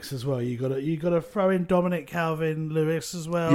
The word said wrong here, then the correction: Dominic Calvert-Lewin: Dominic Calvert-Lewin.